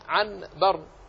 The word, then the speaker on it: عنبر: العنبر هي أصلها عن بر، وتكتب عن بر بالنون، لكن النطق عنبر، عنبر، ولا أحد يقول عن بر، وإنما هي عنبر. قلبت النون إلى ميم ساكنة ثم أخفيت في الباء، عنبر.